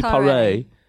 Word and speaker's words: Paré: She's got an accent on the E, so it must be Paré.